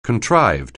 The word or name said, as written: contrived